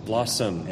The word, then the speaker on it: Blosxom: Now, it seemed appropriate for Rail to come up with that because he wrote a blogging engine that I can't pronounce, Blossom. – Blossom.